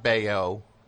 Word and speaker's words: Baio: How do you say that name? I always get it confused. – Well, you got that right. Scott Baio and Born on the Bayou.